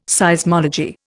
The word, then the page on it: Seismology: Indian